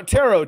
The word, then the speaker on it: tarot: Uh no, tarot, T A R O T. The T Ooh. is silent.